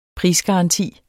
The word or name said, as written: prisgaranti